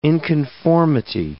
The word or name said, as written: inconformity